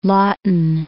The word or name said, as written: Laughton